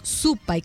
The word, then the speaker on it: Supay: Y como decía Marcelo, Supay con acento en la U,